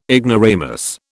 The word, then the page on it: ignoramus: British English